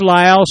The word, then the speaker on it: Lisle: Please write Signs, spelled S-I-G-N-S, P-O, Box 122. Town is Lyle, spell that, L-I-S-L-E.